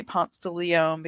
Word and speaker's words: Ponce de Leon: I won't say Ponce de Leon because everyone makes fun of me, but that's how we pronounce it here.